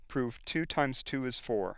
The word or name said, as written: prove